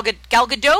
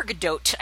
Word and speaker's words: Gadot: Gal Gadot or Gadot? I think you pronounce the T apparently, but she's fantastic.